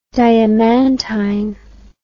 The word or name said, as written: diamantine